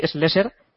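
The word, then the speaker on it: Schlesser: De Schleser, por favor, respeto a don Jean-Louis Slesser, leyenda del Dakar y de los campeonatos de Raids. Por favor. ¿eh? – Bueno, pues si la pronunciación la tenéis ahí, Slesser, que a falta de dos,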